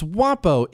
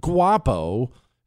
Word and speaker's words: guapo: It's Wapo, idiot. The G is silent. See, this is why you don't habla, all right? You didn't take, it's not Guapo. It's Wapo, I think.